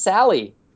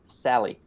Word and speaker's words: Sale: It's pronounced Josh Sale. Everybody knows Josh Sale's name is Josh Sale. It's pronounced Sally. – Huh. Now that you say that, I feel like I know that. – S-A-L-E. Sally.